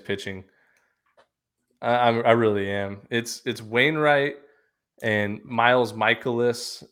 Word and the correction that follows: Mikolas: and Miles Mikolas. Okay. That's how you say it in the true term.